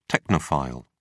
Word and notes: technophile: British English

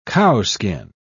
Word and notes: cowskin: British English

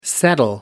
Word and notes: settle: American English

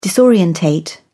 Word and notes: disorientate: British English

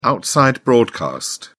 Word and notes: outside broadcast: British English

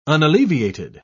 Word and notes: unalleviated: British English

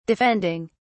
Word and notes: defending: British English

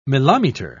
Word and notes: mileometer: British English

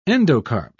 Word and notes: endocarp: British English

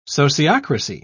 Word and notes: sociocracy: British English

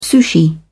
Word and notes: sushi: British English

